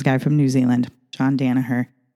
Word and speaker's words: Danaher: then you probably have heard the name John Danaha. Sorry, I don't know. I have to say his name